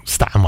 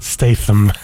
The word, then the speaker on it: Statham: Jason Statham, Statham I call him, it's a bit like how I pronounce, for example, Birmingham. – Yeah. – The H is silent, not Statham.